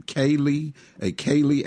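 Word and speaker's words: céilí: Now, there is a, let me pronounce it right, is it a Kaylee after party? – That's correct. After we close at 5, we'll shut down for a little bit just to get everything rearranged.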